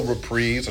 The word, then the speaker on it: reprise: And since we were dealing with Spelling Bee, just a reminder, it is spelled R-E-P-R-I-S-E, and that's why we say reprise. If it were spelled R-E-P-R-I-Z-E, like a reprise, to get a prize a second time, then we would pronounce it that way. But we will always say reprise, and it will continue to be a reprise, not a double reprise or a triple reprise, okay?